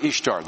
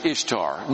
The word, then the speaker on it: Ishtar: Second, is this how you pronounce Ishtar? No, not even close. First of all, just look at the English word. Divide between the consonant, I-S-H. What is that? Ish. That's not east, you know, and tar, not tur.